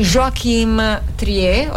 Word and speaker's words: Joachim Trier: di Joachim Trier, ho detto bene? – Sì, è norvegese, mm-hmm, poi hai detto bene,